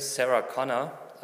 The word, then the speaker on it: Sarah Connor: Die deutsche Sängerin Sarah Connor, also wird amerikanisch ausgesprochen, äh, habe letzten Lied von ihr gehört.